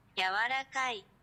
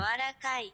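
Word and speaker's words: Yawarakai (柔らかい): Yawarakai. – You hear that? – Say it again. – Yawarakai. – I can't hear you. – Yawarakai. – Yawarakai. – Yawarakai. – Yawarakai. You are soft. – Yawarakai.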